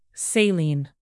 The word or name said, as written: saline